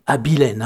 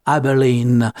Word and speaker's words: Abilene: George Hamilton avec son I Berlin, quand, comme ils disent en Amérique, parce qu'en français ça se dit Abilene, hein, comme je l'ai prononcé dès le début du morceau. Mais eux, ils aiment bien dire Abilene.